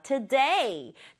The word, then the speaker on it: today: today. today는 뭐냐면 오늘이라는 말이에요. 앞에 to가 있지만 발음이 to 아니고요. t예요. t. 그리고 강세를 여기 콕 줘서 조금 전에 써봤던 day라는 말 today. today 이렇게 읽어주면 좋겠죠.